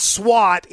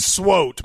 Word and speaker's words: SWOT: First of all, it's SWO. Russ, it's SWOT. That is not SWAT anywhere except ESPN. That's SWOT.